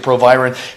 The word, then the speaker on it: Proviron: I knew a guy who worked actually for the company that originally sold it, and he pronounced it Provorin. I know everyone else insists on pro- pronouncing it Proviron. He may have been the asshole and wrong, but I picked up that habit from him, so I call it Provorin.